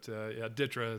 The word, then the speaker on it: DTRA: Yeah, so I had not heard of it either before I went there, but DTRA is how you pronounce it.